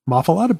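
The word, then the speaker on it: muffuletta: Muffalata? – I thought that was nice. Muffalata bread. – That's how you pronounce it.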